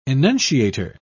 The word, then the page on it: enunciator: British English